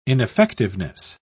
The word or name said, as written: ineffectiveness